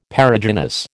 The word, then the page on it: Perigynous: American English